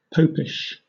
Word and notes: popish: British English

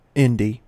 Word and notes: indie: American English

